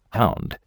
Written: hound

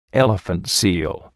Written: elephant seal

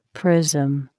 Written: prism

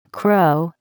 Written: crow

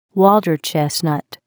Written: water chestnut

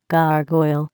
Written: gargoyle

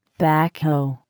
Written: backhoe

